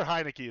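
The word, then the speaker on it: Heinicke: I thought their defense was going to keep them in some games, but Tyler Hinkie is Taylor Hinkie. However you say his name, Mr. Heineke, or Heineke. I'm sorry, not Hinkie. Who am I thinking of? Taylor Heineke is terrible.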